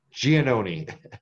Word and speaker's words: Giannone: Nope, I'm going to say your last name for me again, Nick. – Giannone.